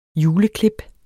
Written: juleklip

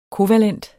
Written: kovalent

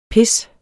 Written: pis

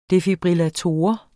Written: defibrillator

